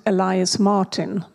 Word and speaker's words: Elias Martin: No, you pronounce it Elias Martin, but the Swedish artist.